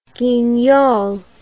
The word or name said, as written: guignol